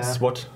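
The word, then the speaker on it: Złoty: Moment, wie spricht man Slotty aus? SWAT. SWAT.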